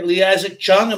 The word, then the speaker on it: Lee Isaac Chung: Lee Isaac Chung, am I saying that right? – Yeah.